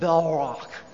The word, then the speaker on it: Balrog: You have to say it like that, Balrog.